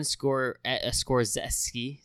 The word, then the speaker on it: Scorsese: We have Quentin Tarantino with Once Upon a Time in Hollywood, Todd Phillips, Joker, Martin Scorsese.